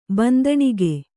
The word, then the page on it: ಬಂದಣಿಕೆ: bandaṇike; Kannada